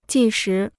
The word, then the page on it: 计时: jì shí